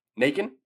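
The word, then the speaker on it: Nakken: Alyssa, I want to say her last name is pronounced Nakin or Nakin. Nakin? Perfect.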